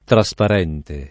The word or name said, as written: trasparente